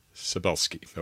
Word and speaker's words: Cybulskie: Cybulskie.